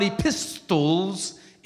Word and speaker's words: epistle: It's not epistles, it's epistle.